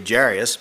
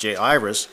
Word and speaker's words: Jairus: I think if I'm pronouncing that right, according to the way my Bible's marked here, sometimes we say Jairus, but I think it's pronounced Jairus, the ruler of the synagogue.